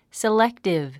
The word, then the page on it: selective: siléktiv; セレクティブ